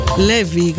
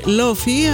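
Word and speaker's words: Laufey: Η Ισλανδοκινέζα Λέβι. Γράφεται Λόφι, αλλά προφέρεται Λέβι.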